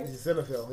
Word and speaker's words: Zinfandel: And then Zinfandel. Am I saying it right? – Zinfandel, yep.